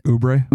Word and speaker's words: Oubre: Oh, Kelly Oubre Jr.? – How do you say it? – Oubre. – Oubre? Yeah.